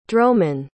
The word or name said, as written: dromon